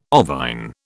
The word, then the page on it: ovine: British English